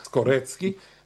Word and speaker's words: Skorecki: Karl Skorecki, and he pronounces the C's as C. It's not like Morocki, which is Mrocki, or it's not like Slaki, which is Slutski. He's a real Skorecki, and he is an expert of that.